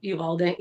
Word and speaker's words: Uvalde: It wasn't just Uvalde, or am I saying that right? – Yeah, Uvalde, yeah.